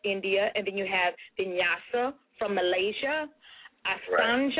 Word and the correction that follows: Ashtanga: Am I saying that right? – Ashtanga, yeah, but it's perfectly fine.